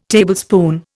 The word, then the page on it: Tablespoon: Indian